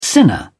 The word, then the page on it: sinner: British English